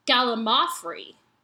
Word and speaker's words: gallimaufry: I would have written sooner, but expected that some other nobody would have let you know by now that the correct pronunciation of gallimafry puts the emphasis on the third syllable, mau, not the second, lim. As you've been saying it. So it's gallimaufry.